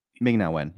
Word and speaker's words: Ming-Na Wen: I didn't think that I'm going to mispronounce her name. Ming-Na Wen? – Yeah, it's pretty much how you say it. – I really didn't. – Is it not Ming? – Ming-Na Wen, yeah.